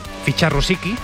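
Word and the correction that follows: Rusowsky: Se dice así, ¿no? – Rusovsky. – Ah, Rusovsky.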